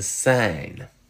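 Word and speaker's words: scène: A penny is une sen, but a stage is une sine. Can you tell the difference? The second word, sine, is longer.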